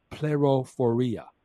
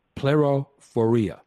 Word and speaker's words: plerophoria: In Colossians chapter 2, verse 2, and Hebrews chapter 6, verse 11, the word assurance is the Greek verb pleroforia, pleroforia. That's spelled P-L-E-R-O-P-H-O-R-I-A, pleroforia.